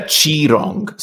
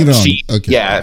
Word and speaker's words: Qirong: I've said already, you play, I can never pronounce his name, is it Qirong or is it it's a Qirong, so like Qirong. The Q is actually like a CH noise.